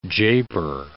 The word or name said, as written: japer